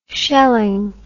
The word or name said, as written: shelling